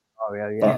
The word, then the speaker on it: Vicario: Vicario, is it? – Vicario, yeah.